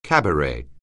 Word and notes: cabaret: anglais

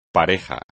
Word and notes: pareja: espagnol

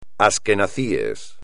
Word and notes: asquenazi: espagnol

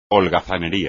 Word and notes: holgazanería: espagnol

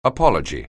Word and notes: apology: anglais

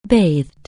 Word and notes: bathe: anglais

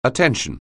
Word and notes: attention: anglais

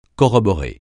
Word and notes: corroborer: francais

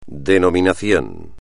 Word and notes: denominación: espagnol

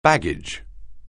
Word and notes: baggage: anglais

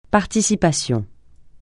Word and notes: participation: francais

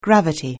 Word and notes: gravity: anglais

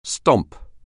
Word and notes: stomp: anglais

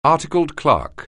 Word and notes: articled clerk: anglais

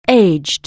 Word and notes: age: anglais

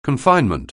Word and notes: confinement: anglais

